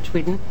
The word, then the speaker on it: Tvedten: I'm Christina Tweeten. That's spelled T-V-E-D-T-E-N.